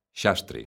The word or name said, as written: xastre